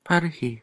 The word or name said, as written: par-hi